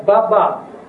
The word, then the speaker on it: Баба: Только теперь имя владыка Баба, ударение на последнем А.